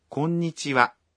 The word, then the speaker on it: こんにちは: It's the same as in the wa in こんにちは. – Hello. We pronounce it wa, but spell it ha.